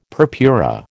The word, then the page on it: purpura: American English